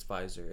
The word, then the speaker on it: Pfizer: I think the P's silent. I'm like fairly Pfizer? confident okay. that it's Pfizer.